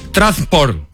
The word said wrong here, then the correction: Trabzonspor: No, nunca lo vas a poder decir. Trabzonspor.